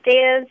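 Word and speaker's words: Deya: Hi, my name is Daya Zavalas.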